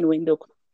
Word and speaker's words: Windhoek: So you live in Windhoek? If I pronounce yes, that. I live in Windhoek.